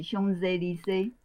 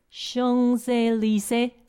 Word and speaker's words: Champs-Élysées: 那个香榭丽舍大道哈是 Champs-Élysées， Champs-Élysées，这样对吗？Champs-Élysées，就是丽玲除了这个词之外啊，因为丽玲通常上面这个主词哈，主要的词汇之外，它下面都会配一篇，就是他自己的观察哈。